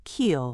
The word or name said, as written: keel